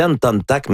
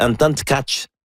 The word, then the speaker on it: Anton Tkach: mais c'est pas la façon de la prononcer. Il y a Yuraï Benko qui nous mentionne aujourd'hui qu'on prononce Anton. Il dit prononcez bien vite en tchèque Anton Tkach.